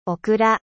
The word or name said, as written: gombo